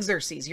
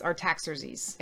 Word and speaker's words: Artaxerxes: It's not Xerxes. You're thinking it's Xerxes, but it's not. It's Artaxerxes.